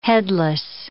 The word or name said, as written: headless